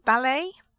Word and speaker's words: ballet: But words like ballet, gourmet or chalet, ballet, gourmet or chalet, you don't pronounce them at all. So it's just an A sound, like holiday, but in writing it's ET, and you don't pronounce the T there.